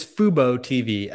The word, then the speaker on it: Fubo TV: Or Fubo TV, rather. – Fubo TV. Wow, we've both butchered the name. It is Fubo TV, FUBOTV.